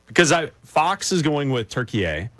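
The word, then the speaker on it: Türkiye: They pronounce it turkey A. – Turkey A. – Okay, like, so over we'll there, it's pronounced turkey A.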